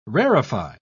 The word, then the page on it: rarify: British English